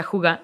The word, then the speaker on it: hygge: Entonces, esto es, eh, hay un concepto danés que me encanta que se llama hige, juga, H-I, eh, H-Y-G-G-E, pero se pronuncia juga,